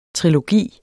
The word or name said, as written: trilogi